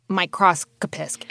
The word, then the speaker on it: microscopist: So you had to become an expert microscopist.